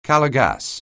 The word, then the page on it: Calor gas®: anglais